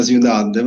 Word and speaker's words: Ciudad: Ma diciamo la pronuncia, ha detto Ciudad, si pronuncia Ciudad, però per il resto, no, il libro è di fantascienza, però non c'entra niente con le criptovalute.